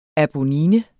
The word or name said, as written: ABONNINES